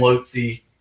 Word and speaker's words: Lhotse: And Maurice wrote the rest of the lyrics on Hole, Cinder, and Lhotse. – That's how you say that name. Dude, I was trying to figure out how to say that song name. – Lhotse.